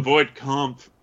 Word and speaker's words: Voight-Kampff: The Voight-Kampff test. – Yeah, that's how it's pronounced.